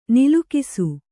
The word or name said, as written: ನಲುಗಿಸು